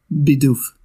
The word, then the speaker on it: Bidoof: It's the correct way it's pronouncing is Bidoof. according to the anime. I know some people like to call it Bidoof or Bidoff.